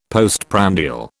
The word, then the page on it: postprandial: British English